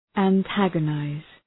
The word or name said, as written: antagonize